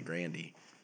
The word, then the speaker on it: Grande: So, like, these people realized that they have been saying Ariana Grande when it was really Grandy.